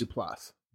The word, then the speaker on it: Duplass: Is it Duplass or Duplass? – Duplass.